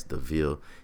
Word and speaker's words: DeVille: That's DeVille. DI is how you say that, just for y'all that be out there that be butchering my name.